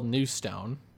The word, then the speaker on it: Newstone: Um, so I came up with Newston, spelled Newstone, but it's pronounced Newston.